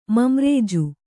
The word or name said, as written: ಮಂರೇಜು